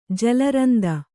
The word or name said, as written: ಜಲರಂದ